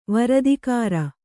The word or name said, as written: ವರದಿಗಾರ